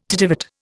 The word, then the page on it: tittivate: Indian